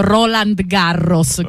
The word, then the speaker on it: Roland Garros: Sia io che Giulio abbiamo sempre detto Roland Garros, confermiamo. – Roland Garros, okay, esatto.